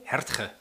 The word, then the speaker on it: Hartge: En motorisch is deze auto aangepakt door, ja, hoe spreek je dit uit? Harge of Hartge? – Hertge. – Hertge. – Is het volgens mij.